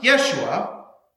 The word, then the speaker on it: Yeshua: Joshua, in Hebrew, the name is pronounced Yeshua, whose name centuries later would be translated into Greek, rendered as Jesus, which then is translated into English as Jesus.